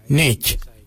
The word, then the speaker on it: ngeidj: For I, we say nech. Nech is spelled N-G-E-I-D-J.